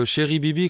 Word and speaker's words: Chéri Bibi: Ah oh, ben comme ça se prononce, Chéri comme Chéri et Bibi comme Bibi Fricotin.